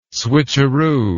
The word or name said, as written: switcheroo